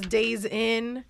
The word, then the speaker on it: DAZN: Like, no, you said it right the first time. Everyone says Days In.